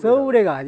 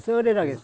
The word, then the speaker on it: 써래: 옛날에 보면 써우래라는 게 써우래 써우래가 아니고 써래라고 했어.